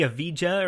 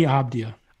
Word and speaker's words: Avdija: It's not Denny Avija, or I can't say his name to save my life. So you're afraid of it. – Emac actually corrected me. Shout out to Emac. – What is it? – It's Denny Avdija.